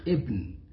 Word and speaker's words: ابن: بن فلان، وهذا ليس صحيحا، هي تكتب بن إنما تنطق ابن، إنما بن وبنسل ده في الإنجليزي.